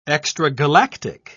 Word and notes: extragalactic: British English

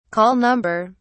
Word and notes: call number: British English

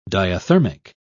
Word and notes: diathermic: British English

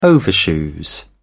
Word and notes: overshoes: British English

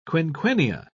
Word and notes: quinquennia: British English

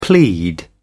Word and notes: plead: British English

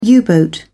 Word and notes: U-boat: British English

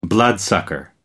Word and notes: bloodsucker: American English

